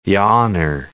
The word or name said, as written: yawner